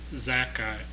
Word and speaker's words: Zakkai: His name was Johanan ben Zakkai, or Jack. You see how unfamiliar we are with these names? Z-A-K-K-A-I. Can you help me pronounce that? Okay. Zakkai.